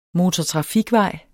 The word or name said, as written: MOTORTRAFIKVEJS